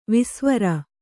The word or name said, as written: ವೇಸವಾರ